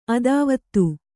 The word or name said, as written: ಅತಿವಾದಿ